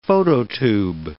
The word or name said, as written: phototube